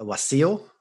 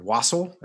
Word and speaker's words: wassail: Well, for my drink, I made a wassail or wassail, as Americans say.